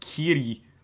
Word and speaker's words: Killy: Yeah, is pronounced Kiri, but it is spelled K-I-L-L-Y. – Yeah, yeah. And I looked because in the manga, it is Killy, K-I-L-L-Y.